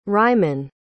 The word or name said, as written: ryman